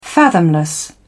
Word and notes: fathomless: British English

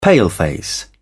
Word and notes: paleface: British English

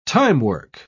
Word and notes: timework: British English

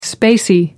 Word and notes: spacey: American English

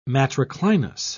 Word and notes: matriclinous: British English

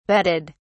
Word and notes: betted: British English

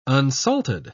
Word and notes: unsalted: British English

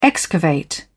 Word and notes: excavate: British English

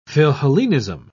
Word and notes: philhellenism: British English

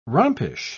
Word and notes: rompish: British English

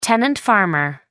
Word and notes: tenant farmer: American English

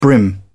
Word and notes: brim: British English

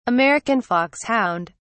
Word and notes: american foxhound: British English

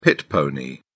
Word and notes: pit pony: British English

